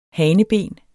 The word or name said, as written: haneben